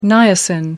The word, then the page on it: niacin: American English